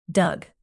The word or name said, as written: dug